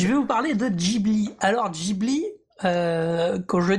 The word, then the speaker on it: Ghibli: On le connaît nous comme ça, alors ça se prononce Ghibli à la japonaise. – Ghibli, on est d'accord.